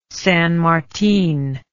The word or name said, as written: San Martin